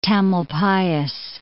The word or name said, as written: Tamalpais, Mount